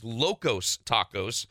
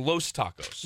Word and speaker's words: Locos Tacos: As a man married to a Mexican woman, can you please tell it is Locos Tacos? – Oh, I called. – He keeps saying it wrong, Los Tacos.